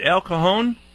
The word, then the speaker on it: El Cajon: Am I saying that right, Sharon? Is it El Cajon? – Yes, it is.